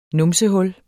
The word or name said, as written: numsehul